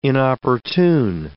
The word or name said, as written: inopportune